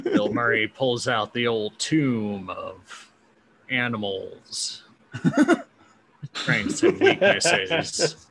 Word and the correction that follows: tome: That was Tome. – I'm sorry, Tome. I pronounced it wrong.